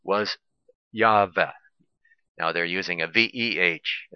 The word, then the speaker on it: Yahweh: Christians erroneously pronounce Yahweh as Jehovah. Well, now they're changing to Yahweh, but still there is no letter like that in the Hebrew alphabet, and then they have another excuse about that, about it.